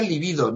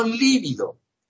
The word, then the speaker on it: libido: Por cierto, se pronuncia libido, no líbido. Líbido, como si fuera esdrújula, con tilde y con V, es quedarse pálido, ¿vale? Entonces, no hay que quedarse pálidos, ¿no? Tenemos que pronunciar libido, como si fuera llana. Libido,